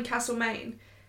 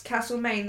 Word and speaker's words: Castlemaine: My mum was born in Castle, Maine. – It's cold. – No, but that town is pronounced Castlemaine. The people who live there pronounce it Castlemaine.